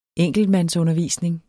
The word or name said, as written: enkeltmandsundervisning